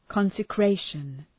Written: consecration

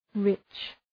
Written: rich

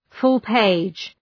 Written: full-page

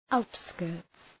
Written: outskirts

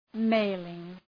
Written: mailing

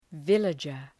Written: villager

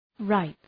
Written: ripe

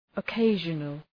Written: occasional

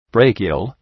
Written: brachial

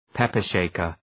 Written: pepper shaker

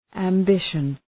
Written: ambition

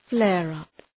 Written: flare-up

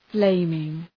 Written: flaming

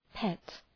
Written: pet

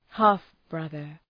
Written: half-brother

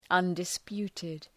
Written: undisputed

